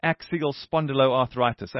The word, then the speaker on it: axial spondyloarthritis: And that helps, that makes me realize I don't know, and maybe the others listening also don't know enough about axial spondyloarthritis. Am I on the right track with my pronunciation there? – You're spot on.